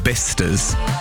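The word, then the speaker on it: Bicester: I want your Worcesters, your Bisters, all these places. If it's Bister, then spell it properly.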